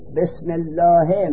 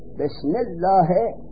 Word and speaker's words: بسم الله: همزه را نوشته به اسمه، لیکن تلفظ نمی کنیم، بار بسین وصل میکنین در تلفظ. بسم الله با وصل شد بسین، اما نوشتنش می نویسین، تلفظ نمی کنین.